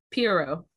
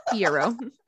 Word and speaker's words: Piero: And so he says something to like Perot. Is what how do you say it again? – I don't remember. – Piero. Okay. – Yeah. P in a row. I remember now. Piero.